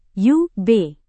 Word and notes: ub: Indonesian

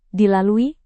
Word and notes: dilalui: Indonesian

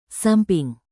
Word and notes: samping: indonesian

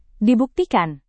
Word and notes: dibuktikan: Indonesian

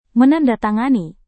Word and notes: menandatangani: Indonesian

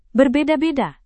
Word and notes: berbeda-beda: indonesian

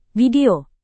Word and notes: video: Indonesian

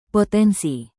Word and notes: potensi: Indonesian

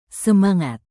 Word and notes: semangat: Indonesian

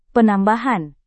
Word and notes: penambahan: Indonesian

